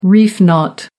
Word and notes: reef knot: American English

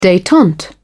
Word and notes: detente: British English

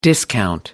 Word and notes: discount: American English

